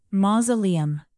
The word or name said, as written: mausoleum